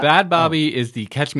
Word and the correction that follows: Bhad Bhabie: I think it's pronounced Bad Baby, but it's spelled... – B-H-A-D-B-H-A-B-I-E. – Yeah, that's bad. That's Bad Baby.